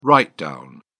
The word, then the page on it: write down: British English